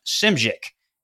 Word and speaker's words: Szymczyk: It's pronounced Szymczyk. Yeah, uh, there's a lot of there's a lot of Z's and a lot of Y's in that. S Z Y M C Z Y K Szymczyk,